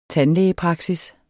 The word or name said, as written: tandlægepraksis